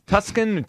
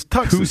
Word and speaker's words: Tucson: I heard some pronunciation issues yesterday with Tuscan. Tuscan? – He was trying to give us a lesson on pronunciation. Yeah. It was just wrong. – No, I know it's Tucson. – No. – It's Tucson.